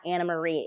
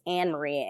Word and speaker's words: Annemarie: It's not Anna Marie, it's Anne Marie.